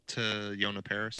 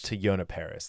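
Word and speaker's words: Teyonah Parris: To Yona Paris. – To Yona Paris.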